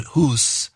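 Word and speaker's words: Hus: Rex as John Huss, or as Dr. Lutzer pronounces it, Huss, because it rhymes with goose, at being burnt at the stake.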